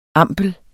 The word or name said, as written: ampel